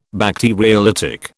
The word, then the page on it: Bacteriolytic: British English